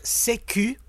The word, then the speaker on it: sécu: So CQ would be pronounced in French, sécu, from the French word sécurité.